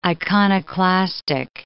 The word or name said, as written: iconoclastic